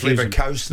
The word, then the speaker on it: Leverkusen: So can someone tell me, is it Kewson or Kausen? – I just let you go yesterday when Leverkusen. It was Leverkusen.